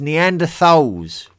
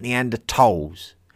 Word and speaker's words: Neanderthals: It is Homo sapiens with a long A, not sapiens. It is Neanderthals with a th and not Neanderthals.